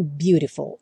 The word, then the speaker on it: beautiful: O azul claro é quando o T ou o D passam a ter o som rrrrr, como por exemplo na palavra beautiful, que se escreve com T, seria beautiful, mas a maioria dos americanos falam beautiful.